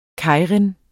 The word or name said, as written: keirin